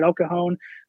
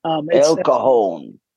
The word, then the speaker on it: El Cajon: That's how you say it, El Cajon. – El Cajon. – It's a J, but tell us more about your ministry and about yourself.